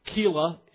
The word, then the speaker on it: Keilah: Meanwhile, back in the forest, Keilah, K-E-I-L-A-H, and those of you who take exception to my Hebrew, that's fine. I know that it's actually pronounced Keilah, but that's fine. Keilah is just fine. It's pronounced Keilah today.